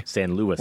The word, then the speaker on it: San Luis: San Luis.